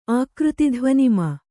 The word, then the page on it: ಆಕೃತಿಧ್ವನಿಮಾ: ākřtidhvanimā